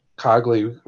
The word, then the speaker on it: Cogley: The law books, you know, and Neek's recap touched on that also with that Cogley reference.